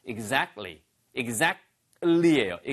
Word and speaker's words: exactly: Exactly라고 안 들리고요. Exact 한 다음에 그으 소리 때문에 트 발음이 잘안 들려요. 그래서 사실은 exactly가 맞지만 들리는 발음은 exactly.